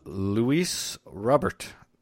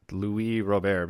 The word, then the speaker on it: Luis Robert: Luis Robert signs with the White Sox, not Louis Robert, because he's not a Frenchman.